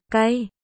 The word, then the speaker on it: cay: Thương Nguyệt, lay, không đúng, phát âm là L, cay, A, cay.